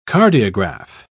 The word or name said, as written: cardiograph